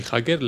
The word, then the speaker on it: hacker: H-A-C-K-E-R, joder. – Hacker, aunque la pronunciamos con J, la escritura es con H.